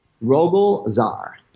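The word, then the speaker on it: Rogol Zaar: Well, truly, two great stories because the Rogel Zal, or Zar story, how do you say his name? – Rogel Zar. – Zar, okay, that's right.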